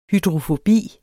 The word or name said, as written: hydrofobi